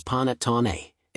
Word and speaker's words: panettone: The word panettone, meaning a large cake, is pronounced as panettone, and its history dates back to the Roman Empire.